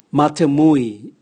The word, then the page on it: matemui: Raga